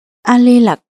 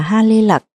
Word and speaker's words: A Lê Lặc: A Lê Lặc, tên gọi được phiên âm từ Phạn ngữ Haritaki, nên trong một số kinh luật cũng được đọc là Ha Lê Lặc.